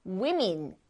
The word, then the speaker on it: women: 그런데 발음이 다르죠. 아까 여기 있는 복수형, man의 복수형, man 발음 똑같았는데요. woman, 그 다음에 women 이렇게 발음합니다.